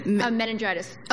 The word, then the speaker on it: Meningitis: Meningitis. – Oh, right. Oh, I thought you had a very fancy word. So it was a. It was like I don't know. – Meningitis.